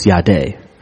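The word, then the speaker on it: yadeh (ידע): It's Y-A-D-A. You would think it's pronounced yada, but it's yadeh. And it means to perceive and see and discern. And understanding is pronounced being, B-E-N-E, and it's the same thing.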